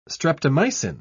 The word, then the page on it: streptomycin: British English